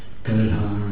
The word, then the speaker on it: Gadadhar: If you say Gada, Gada, Gada, Dara, the word Gada means ass, or one holds the ass. So you have to be careful when you pronounce Gadadhar's name. Please say it correctly.